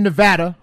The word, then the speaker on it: Nevada: Living in Nevada, hard A. – Thank you. The correct pronunciation.